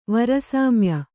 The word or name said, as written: ವರಸಾಮ್ಯ